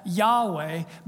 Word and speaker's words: Yahweh: They just put YHWH. We pronounce that Yahweh,